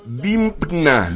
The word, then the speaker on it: Dympna: Oiga usted este nombre, Dimpna. Dimpna. Está hasta difícil de escribir, se escribe D-Y-M-P-N-A. Dimpna.